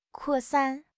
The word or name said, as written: 扩散